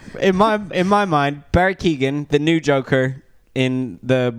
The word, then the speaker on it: Keoghan: I thought Barry Keoghan, which I for sure know how to say his name now. I was unsure if it was Keegan.